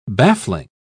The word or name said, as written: baffling